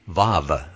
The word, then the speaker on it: vav: V as in vine, vav, V-A-V, the first Hebrew primer for adults.